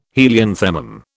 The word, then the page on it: helianthemum: British English